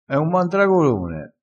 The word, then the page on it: mandragolone: dialettale